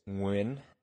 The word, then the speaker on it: Nguyen: It's a phoneme that the English language just doesn't have, which is a subtle NG roll with the back of the tongue. I've tried to teach people how to say it. It's Nguyen, but